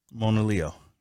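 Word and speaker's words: Monaleo: It's Mona Leo. – Well, you got an A. – I sure do. I sure do got an A up there. I sure do. It's a silent A. It sounds like. – So as you can see. – Sounds like an O.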